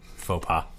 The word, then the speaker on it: faux pas: I was saying them phonetically. Fauks pass? I don't know. – Faux pas. That's what you're trying to say. Faux yeah, but I was saying it phonetically. Are you shocked that I know what phonetically means? – Except that phonetically it's pronounced faux pas. – Yeah, but... – Phonetically it would be F-O-H-P-A-H. Faux pas.